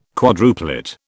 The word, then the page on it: quadruplet: British English